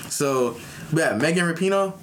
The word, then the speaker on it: Rapinoe: You know, I'm like but then I said it at the same time, like, no, let me correct him this time. It's Megan Rapino.